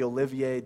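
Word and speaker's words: Olivet: And so often you'll hear this whole chapter of Mark and the other places it is in the other Gospels called the Olivier Discourse, because it's on the Mount of Olives. And since you're academic, you have to have a silent T.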